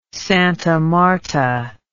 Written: Santa Marta

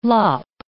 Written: lop

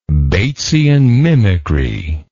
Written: Batesian mimicry

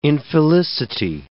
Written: infelicity

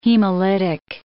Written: hemolytic

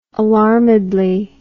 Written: alarmedly

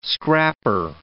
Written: scrapper

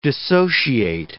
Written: dissociate

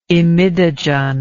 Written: imidogen

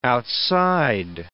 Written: outside

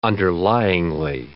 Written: underlyingly

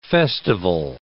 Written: festival

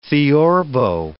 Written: theorbo

